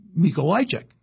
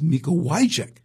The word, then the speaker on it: Mikołajczyk: And I say, do you know how to pronounce this? And she says, Mikołajczyk.